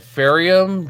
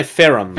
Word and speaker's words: Ferrum: Uh, the Ferium drone base. I would say Ferrum. – Ferium. – Ferrum. Ferrum. There's no I.